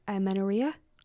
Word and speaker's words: amenorrhea: and she was talking about the effects that amenorrhea, did I pronounce that right? – Yeah.